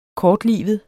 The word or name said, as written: kortlivet